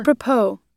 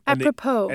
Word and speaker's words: apropos: Apropos. – No. – That's how you pronounce it. – Apropos.